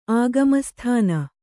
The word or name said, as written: ಆಗಮಸ್ಥಾನ